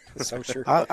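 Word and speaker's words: Saucier: Am I saying that right? – Soshiay? Grand Bear will be in Gulfport. – Well, they have it listed as Soshiay. So Socher? – Socher. – It's spelled one way and it's pronounced another.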